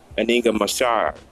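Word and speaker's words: Inga Marchand: I want to talk about Foxy Brown, aka Aninga Mashar. That's how you say it.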